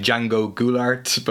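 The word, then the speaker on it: João Goulart: And it's the name, you'll tell me how to pronounce it. I mean, Django Goulart, but that's fine. Yeah, okay. That's the best way to say it in English. If I said, you say in Portuguese, it's Django Goulart.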